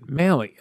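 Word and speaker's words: Mahle: I figured out how to say his name. If you caught last episode, I think we went through this earlier in the season. Malley, like Malley's Chocolates here in Cleveland.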